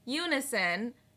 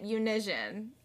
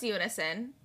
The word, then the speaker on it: unison: I just want everyone to know that we had to re-record that because I do not pronounce that word unison. I pronounce it unision. And I always have and I always will. – She made fun of me for how long a few weeks ago, guys, for saying dazzlingly. And she can't fucking say unison. – Well, the thing is, I know it's unison. I recognize it's unison.